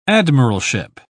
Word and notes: admiralship: British English

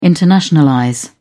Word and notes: internationalize: British English

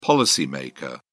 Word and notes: policy maker: British English

